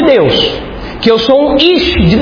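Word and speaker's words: ish (איש): A mulherada já fala ish. Ish. Homem em hebraico é ish. E fogo em hebraico é esh. É próximo ou não? Ish e esh.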